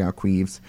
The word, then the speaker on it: ArQuives: It is the archives, and the website for that is arquives.ca. That's arquives.ca. So archives with a Q, not to be pronounced archives, which is what I've been calling it all morning.